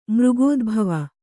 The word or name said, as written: ಮೃಗೋದ್ಭವ